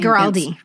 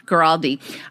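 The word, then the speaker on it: Guaraldi: I remember when I got my Charlie Brown, Vince, how do I say his name? – Giraldi. – Giraldi.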